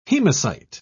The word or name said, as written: hemocyte